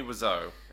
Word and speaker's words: Wiseau: Tommy Wiseau. And that's how it's pronounced. Wiseau, according to the book.